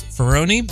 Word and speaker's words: Ferroni: I switched pronunciation because I do believe that he prefers Ferroni, but, you know, people say it both ways.